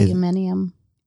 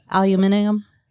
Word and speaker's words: aluminium: There's a word, and you guys may be able to pronounce this better than me, so I know that the Brits pronounce aluminum in a different way. – Aluminium. – Yes. – Yeah, they hit all the syllables.